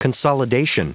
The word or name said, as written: consolidation